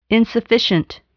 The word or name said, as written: insufficient